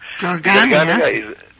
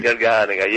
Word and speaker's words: Garganega: that is the Garganega, a very difficult to be pronunciated by Americans, I know. – Garganega? That's okay. Garganega, yes. Garganega.